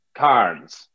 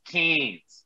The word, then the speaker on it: Cairns: Now you would pronounce it as Cairns right? I, I, guess, perhaps. – Probably. But we we drop the R out. We forget about the R, and it's just Cairns.